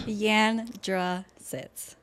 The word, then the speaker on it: Yandrasits: Yandrasits.